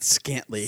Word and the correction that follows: scantily: Scantly. – S-C-A-N-T-I-L-Y. Scantily.